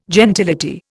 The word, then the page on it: gentility: Indian